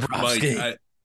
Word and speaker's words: Bobrovsky: I'm going to call him Bobby because I can't pronounce his name. So, look, Bobrovsky. Bobrovsky, he's been great and everything.